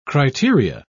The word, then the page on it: criteria: British English